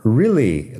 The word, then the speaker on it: really: Really, lo digo así. Personalmente, really, como si fuera una I al principio, pero realmente es EA, realmente.